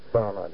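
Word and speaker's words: Barnard: You sent Dr. Christian Bernard. Is that how you pronounce it? – Bernard, yes. – And he's the world's greatest heart surgeon, and he was a pioneer.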